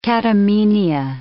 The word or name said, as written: catamenia